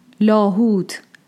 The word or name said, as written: Lāhūt